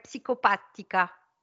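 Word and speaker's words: psicopatica: Psychopatria. – Non, c'est psychopatica.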